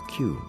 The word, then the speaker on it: queue: Another name for the braid was a queue. That's a hard word to spell, but an easy one to say. It is pronounced just like the letter Q.